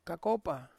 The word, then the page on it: kakopa: Ansus